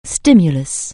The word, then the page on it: stimulus: anglais